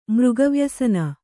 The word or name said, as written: ಮೃಗವ್ಯಸನ